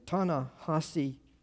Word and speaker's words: Ta-Nehisi: Ta-Nehisi Coates. He writes for The Atlantic Monthly. He's he's kind of a cultural observer. You've run across his stuff often. In an interview I heard on the radio, Ta-Nehisi Coates was being interviewed by a lady.